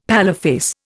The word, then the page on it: paleface: Indian